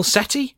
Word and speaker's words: Sethi: It's Neil Sethi. – There is an H in there. Is it Silent H? – Silent H.